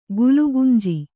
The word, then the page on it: ಗುಲಗಂಜಿ: gulaganji